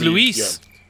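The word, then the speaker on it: Louis: Well, it's spelled like the French way, not the L. – So it's Luis.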